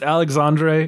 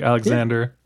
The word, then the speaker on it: Alexandre: It is by Alexander. I does anyone want to help me out with how to pronounce this? Doesn't this shouldn't be a Alexandre? It's it's Alexandre. It's not like Alexander.